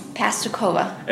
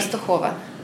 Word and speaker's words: Pastakova: Okay, so what's the American pronunciation? – Pastakova. – And what's the at-home pronunciation? – Pastakova. – Which do you prefer? – I got used to it.